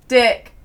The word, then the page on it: dick: British English